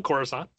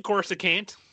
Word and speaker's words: Coruscant: Okay, so that's somewhere on Coruscant. – Coruscant. – Yeah. Let me say it right. Sorry, somewhere on Coruscant, near Emperor Palpatine's quarters.